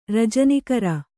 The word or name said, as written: ರಜನಿಕರ